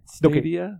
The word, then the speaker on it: Stadia: Are you sure it's not Stadia? – Stadia. You're right. – Have you Stadia. – Heard it pronounced? – Yes, you are correct. – I was just thinking it kind of sounds like Stadium.